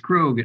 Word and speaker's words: Krog: But it's Krog. It rhymes with Vogue or Rogue.